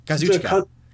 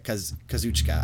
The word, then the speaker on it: Kazuchika: Kazuchika. – It's Kazuchika Okada. – But I think they actually say it Kazuchika. I don't think they actually pronounce one of the syllables in there.